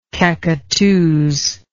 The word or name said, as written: cacqueteuse